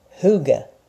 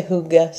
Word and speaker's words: Hygge: Hygge. Some people say Hygge, some say Hygge, which I'm not really sure. I think mostly Americans and, you know, the British say it like that.